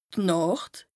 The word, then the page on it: Nàcht: Bas Rhin